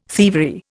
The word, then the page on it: thievery: Indian